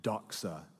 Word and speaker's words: doxa: Here's the Greek word, doxa.